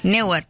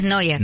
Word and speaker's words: Neuer: Me acabo de dar cuenta que es el arquero que yo pronuncié muy mal. – Neuer. – Y yo dije Neuer. Sí. Y es Neuer.